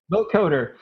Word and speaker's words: vocoder: And when you hear the vocoder, that's how you pronounce it, vocoder, that is supposed to simulate Neil Young's son Ben's inability.